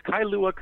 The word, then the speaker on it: Kalua: I hope I said that right. Welcome to the show today. – Great to be here. Thanks for having me. – Absolutely. – It's Kalua, Kona, just to correct the pronunciation there.